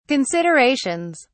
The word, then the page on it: considerations: British English